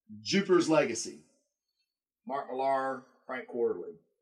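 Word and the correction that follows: Frank Quitely: Quietly. Frank Quietly.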